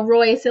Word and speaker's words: Royce: So Royce, it like rhymes with choice.